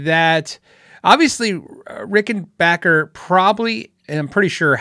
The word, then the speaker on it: Rickenbacker: Today I saw prices for Rickenbackers. Actually, it's pronounced Rickenbacker, if that makes sense.